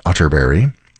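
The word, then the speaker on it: Atterberg: It's spelled Otterberg, but pronounced Otterberg.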